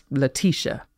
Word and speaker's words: Letitia: Letitia, she says instantly, correcting my pronunciation. It's Letitia.